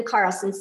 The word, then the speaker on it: Carlson: And my name is spelled A-I-M-E-E and then Carlson, C-A-R-L-S-O-N.